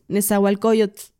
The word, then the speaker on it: Nezahualcóyotl: Es que no es Nezahualcóyotl, o sea, la tl no se pronuncia. Aquí dato cultural para cuando ustedes tengan la oportunidad de pronunciar el tl, no es tl, es t, o sea, como t, Nezahualcóyotl así.